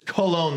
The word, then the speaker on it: Cologne: But in Russian, in English, it's Cologne, like colon.